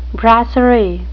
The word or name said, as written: brasserie